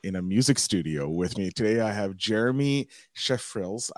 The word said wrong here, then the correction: Schreifels: It's German, so it's Schreifels. – Schreifels.